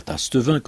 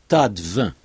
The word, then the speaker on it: tastevin: Il faut savoir qu'on doit dire, non pas tastevin comme je l'ai dit, de façon un petit peu ignare, mais tadevin plutôt que tastevin. Seuls les ignorants prononceront le S, ceux qui ne connaissent pas bien l'histoire de notre belle langue.